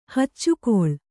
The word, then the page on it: ಹೆಚ್ಚುಕೊಳ್ಳು: kannada